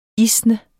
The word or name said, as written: isne